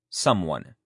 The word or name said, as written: someone